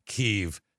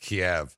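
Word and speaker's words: Kyiv: He said the woman, because it's now pronounced Kiev, not Kiev.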